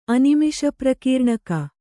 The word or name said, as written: ಅನಿಮಿಷಪ್ರಕೀರ್ಣಕ